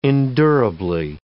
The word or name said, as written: endurably